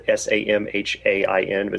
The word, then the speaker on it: Samhain: It's S A M H A I N. It's pronounced Samhain.